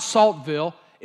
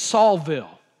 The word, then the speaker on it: Saltville: Now, I was corrected by a Virginian and said it's not Saltville, it's Saulville. But it's spelled Saltville.